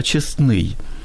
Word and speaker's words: честной: В данном случае даже и нечестный хрест, а честной. Ударение, да, на «о» в данном случае будет.